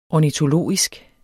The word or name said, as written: ornitologisk